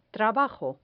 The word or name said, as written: trabajo